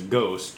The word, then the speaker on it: Gose: And I guess I'm open to drinking like a Gose. – Yeah. – Gose. So there's a Gose, G-E-U-Z-E, and then there's Gose, G-O-S-E.